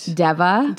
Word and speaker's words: D'Eva: And it was written by a couple called Eric and Diva Redding. – D apostrophe EVA. – How did you pronounce that in your head? – Deva. – Deva. I heard Diva. – I heard Deva and also Deva.